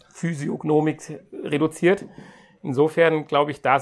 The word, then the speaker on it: Physiognomik: Habe ich das richtig ausgesprochen? Physiognomik, ja.